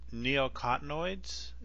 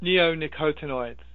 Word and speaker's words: neonicotinoids: The neonicotinoids? Is that how you pronounce it? – Neonicotinoids. – Neonicotinoids. Yeah. Yeah.